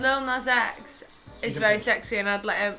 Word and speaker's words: Lil Nas X: Yeah, it's Lil Nas X.